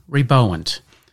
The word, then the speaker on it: reboant: And this week's Word of the Week is rebowant. And that is spelt R-E-B-O-A-N-T.